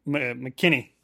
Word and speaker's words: McKinney: Am I saying it right? Xavier McKinnon, right? – McKinney. – McKinney. Xavier McKinney. Okay. I've been saying his name wrong for 2 weeks now.